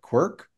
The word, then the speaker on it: Quark: Quark? He doesn't pronounce it right. You have to correct him. Quark.